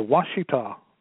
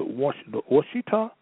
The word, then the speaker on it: Ouachita: The Washita, otherwise known as the Olmec, had been originally associated with the Washita. Say this again. The Washita, otherwise Washita, spelling O-U-A-C-H-I-T-A, sometimes pronounced that way instead of Washita, okay?